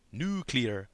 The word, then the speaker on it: nuclear: Contrary to stereotype, Tommy did not pronounce it nuclear, but he did put more emphasis on the first syllable than an urbanite would, nuclear.